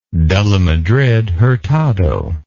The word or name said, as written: de la Madrid Hurtado